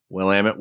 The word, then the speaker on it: Willamette: a recap here for the Willamette Week. I think I said it right. Willamette Week.